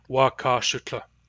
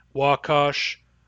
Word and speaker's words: Wakashutla: Wakashutla. This word has many different pronunciations depending on dialect, so you will want to learn for your dialect and from your elders, which pronunciation is used where you are.